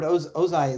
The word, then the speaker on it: Ozai: And when we see the counter to that, those who are afraid to do that, you know, we see Fire Lord Ozai, am I saying his name right? – Yeah, that's exactly okay. – Right. – Good. Fire Lord Ozai is, you know, he is freezing on things.